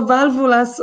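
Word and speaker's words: Válvulas: I słowo valvulas oznacza po prostu zawory. – Mhm. E, dobrze. I teraz tak. Firma Valvulas na trzecią literę akcent.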